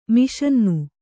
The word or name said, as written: ಮಿಷನ್ನು